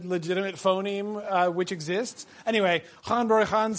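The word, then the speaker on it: Ghân-buri-Ghân: But the woodman, gone, bury, gone, or as I believe it's supposed to be pronounced, and I really can't very successfully, Han Bury Han. The GH is like a voiced sound.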